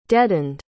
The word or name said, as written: deadened